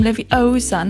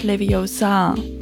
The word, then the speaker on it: Leviosa: So, und dann sagt sie: Besides, you're saying it wrong. It's Wingardium Leviosa, not Leviosa.